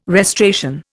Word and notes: restoration: Indian

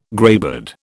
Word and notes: greybeard: British English

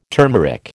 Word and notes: turmeric: American English

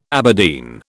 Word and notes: Aberdeen: British English

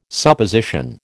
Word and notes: Supposition: American English

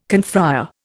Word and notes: confrère: Indian

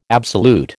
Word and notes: absolute: American English

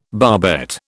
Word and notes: barbette: British English

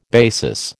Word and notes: basis: American English

